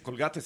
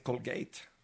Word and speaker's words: Colgate: Bueno, no se dice Colgate, se dice Colgate.